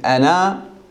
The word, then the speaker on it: أنا: هذا مر معنا، قلنا أن كلمة أنا هذه الألف تلفظ في حال الوصل، عفوا في حال القطح والوقف، وتحذف في حال الوصل، فقط تلفظ الفتحة التي قبلها أنا فعلت، ما تقول أنا فعلت، تقول أنا فعلت.